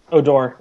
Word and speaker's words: Odor: Odor? – Odor, yeah.